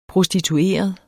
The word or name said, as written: prostitueret